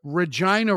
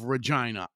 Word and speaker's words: Regina: and he was a star player for the Regina Rats. And even though when you look at the word Regina, it's easy to want to say Regina. It's spelled, in case you don't know, R-E-G-I-N-A.